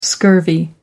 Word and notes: scurvy: American English

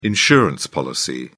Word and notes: insurance policy: British English

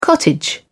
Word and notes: cottage: British English

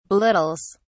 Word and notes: belittles: British English

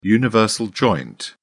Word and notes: universal joint: British English

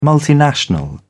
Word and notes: multinational: British English

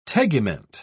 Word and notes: tegument: British English

